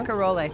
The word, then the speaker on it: Barcarolle: Okay, and he's going to start off the set with Barcarolle, I think. That's how you pronounce that. – Barcarolle. – Thank you.